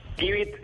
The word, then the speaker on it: Givit: Givit, eh, se pronuncia give Por La palabra en inglés, la frase give de Dalo,